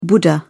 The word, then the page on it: buddha: British English